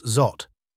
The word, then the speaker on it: XAUT: Enter XAUT, pronounced ZOT, the brainchild of Tether, a company known for its popular USDT stablecoin.